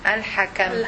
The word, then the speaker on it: Al-Hakam: Al-Hakam. – Al-Hakam. – Al-Hakam. Al-Hakam is the judge. This is number one. The one who makes the final decisions of the nature of all matters. This is Al-Hakam, the judge.